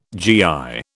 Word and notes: GI: British English

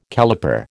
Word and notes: caliper: American English

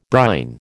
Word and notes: brine: American English

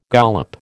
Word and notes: gollop: American English

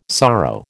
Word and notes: sorrow: American English

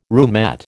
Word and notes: roomette: American English

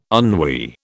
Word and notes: ennui: British English

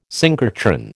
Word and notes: synchrotron: American English

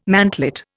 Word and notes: mantlet: Indian

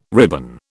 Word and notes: ribbon: British English

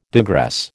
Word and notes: digress: American English

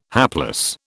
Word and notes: hapless: British English

